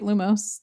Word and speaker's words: Lumos: say Lumos, right? Lumos. That's how you say it.